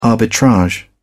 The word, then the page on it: arbitrage: British English